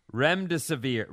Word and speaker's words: remdesivir: Yes, there's a video that actually rolls where it says remdesivir.